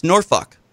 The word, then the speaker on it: Norfolk: Well, so if you talk to a local, they'll say it's Norfolk.